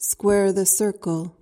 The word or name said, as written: square the circle